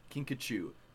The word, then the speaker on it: kinkajou: I think you said that a little wrong. – What? It's a kinkajou. – It's a kinkajou. Kinkajou. Dude, I remember That's a J. – No, it's pronounced kinkajou.